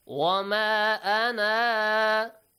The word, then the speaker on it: وَمَا أَنَا: It tells you if you stop at this word, pronounce the alif. If you don't stop at this word, if you continue reading, ignore this alif. Wama ana, you stop.